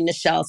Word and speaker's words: Nichelle: Cause so often when people spell my name wrong, or they say Nichelle, like it's their knee, or they put an S in there, or like an A or whatever. But my name is literally Nichelle, spelled like Michelle, just with an N in front of it.